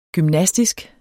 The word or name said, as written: gymnastisk